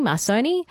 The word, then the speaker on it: Marconi: Marconi? – Marconi.